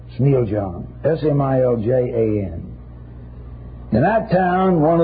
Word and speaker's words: Smiljan: In the year 1856, in the small Yugoslavian town of Smiljan, or Smiljan, pronounce in Yugoslav.